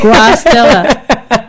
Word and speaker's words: Guastella: I used to tell people, think of guacamole. – Guacamole. – Guastella. It's not hard.